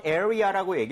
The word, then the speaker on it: area: 자기도 모르게 area. 이렇게 해서 R 사운드 내는 경우 많다. – 맞아요. – Right. 그래서 area 하고 완전 끊어내세요. R 하지 마세요.